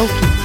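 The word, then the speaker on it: Oakey: om man nu uttalar det rätt där. – Ja. – Namnet uttalas, eller namnet skrivs O.A.K. – O.K. – O.K. – Jag tror att han skulle säga O.K.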